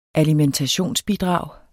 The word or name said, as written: alimentationsbidrag